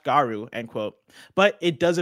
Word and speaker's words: Garou: On stage at EVO 2022, SNK announced that a new entry in the long-dormant Fatal Fury / Garou series, I don't know how to pronounce that one. – Garou? – Okay, perfect.